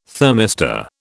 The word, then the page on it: thermistor: British English